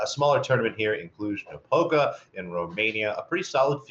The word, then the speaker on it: Cluj-Napoca: Cluj-Napoca. – I think it's Napoca, but I think you're pretty close. I think it is a hard C.